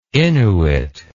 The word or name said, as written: innuit